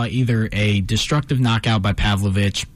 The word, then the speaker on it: Pavlovich: It's thoughts on Blades versus Pavlicic. – Yeah. – Is that how I pronounce it right? – It was Pavlovich. – Pavlovich, gotcha.